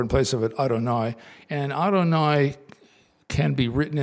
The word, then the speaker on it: Adonai: But when they are placed next to each other in a prayer, they're pronounced Adonai.